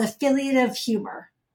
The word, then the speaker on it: affiliative: So I think you alluded to this just a moment ago, but with Laughter on Call, your approach focuses on something called affiliative humor. Affiliative humor. Yeah. – Yes, you said it right.